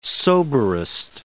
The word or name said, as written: soberest